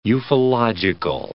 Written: ufological

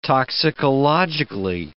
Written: toxicologically

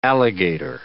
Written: alligator

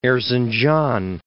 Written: Erzincan

